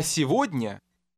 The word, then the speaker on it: сегодня: Once again, the G will sound like V. – А сегодня?